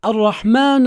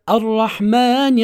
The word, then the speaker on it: الرحمن: ما قلناه في البسملة في كلمة الرحمن أيضا هنا في كلمة الرحمن: لا تجنح منك النون إلى الضم، بدل ما هي مكسورة لا تنطق بها مضمومة، أو توهم السامع أنك تقرأ حرف النون من هذه الكلمة بالضم. لا تقل الرحمن الرحيم، ولكن الصحيح الرحمن الرحيم، هكذا.